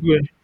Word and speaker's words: Nwigwe: Is that how to pronounce his last Tobe name? Nwigwe. Yeah, Tobe okay, Nwigwe.